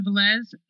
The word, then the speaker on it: Velez: The fundraiser is on my Twitter, which is at Siobhan Velez. It's spelled C-H-E-V-O-N, last name V-E-L-E-Z.